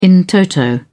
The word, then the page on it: in toto: British English